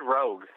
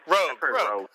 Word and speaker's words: Roeg: He was in a documentary before this, and apparently it was that appearance in a documentary that director Nicholas Roeg? Reg? I don't know how to pronounce that. – I've heard Rogue. – Rogue. Rogue. Let's go with Rogue.